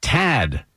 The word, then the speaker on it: Tad: My name is Tad. It's spelled T-A-D. T-A-D. Tad, like just a tad or tadpole.